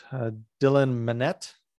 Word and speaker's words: Dylan Minnette: Well, continuing with the cast, Dylan Minnette. Is that how you say it, you think? – Yeah.